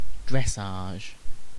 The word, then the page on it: dressage: British English; /ˈdɹɛs.ɑːʒ/